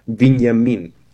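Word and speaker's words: Viñamin (Вениамин): Aunque en ruso o en ucraniano se dice Viñamin.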